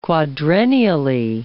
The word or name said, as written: quadrennially